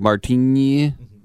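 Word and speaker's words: Martín: And I love the Enye on the Martin. So it's like Martini. – That's exactly how you're supposed to pronounce it.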